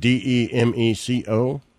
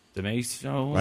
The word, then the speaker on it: DeMeco: I think that's how you say it. DeMeco. – Okay. DeMeco.